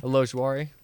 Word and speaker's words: Ojulari: I have them going Azeez Ojulari. Can you pronounce it for me? – Ojulari.